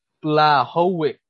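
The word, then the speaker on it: Błachowicz: But Jan Blachowicz, I believe the CH is silent.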